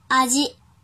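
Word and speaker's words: あじ: 阿基，阿基，阿基，比如尝试味道，阿基米，阿基米，阿基米，写成日语汉字是味道的味加一个看见的见。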